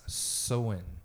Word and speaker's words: Samhain: That's pronounced as Samhain.